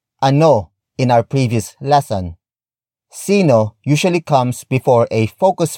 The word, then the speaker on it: sino: Word for word, it's sino, ang, tatay, mo.